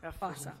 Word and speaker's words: Fossa: Fossa. – Florida, O-S-S-A.